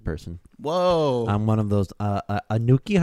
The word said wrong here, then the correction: Anunnaki: How do you pronounce that shit? Anunnaki. Anunnaki.